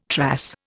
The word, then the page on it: trass: Indian